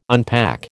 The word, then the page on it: unpack: American English